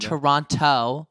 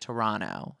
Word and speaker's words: Toronto: Which, by the way, it's not Toronto. I learned from someone from Toronto that you're supposed to say it like Toronto. Not Toronto. – Toronto. It's like a, you know. – Not Toronto. – Toronto is wrong. It's like Toronto. Like Toronto. Toronto.